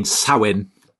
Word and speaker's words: Samhain: Because it's actually in English, it looks like Sam Hain, which kind of sounds like a football manager or something.